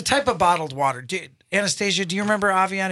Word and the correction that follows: Evian: Have you seen Avion? – Evian. – Evian.